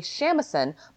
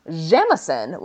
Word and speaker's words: shamisen: The Japanese pronunciation is usually shamisen, but sometimes jamisen when used as a suffix. In Western Japanese dialects and several Edo period sources, it is both written and pronounced as samisen.